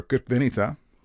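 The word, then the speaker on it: Crikvenica: Anyway, June, founded by the Krkvenica Tourist Board, Blue Fish Month is hosted by the city of Krkvenica, or Krkvenica, accent in the first syllable there.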